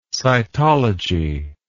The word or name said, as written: cytologist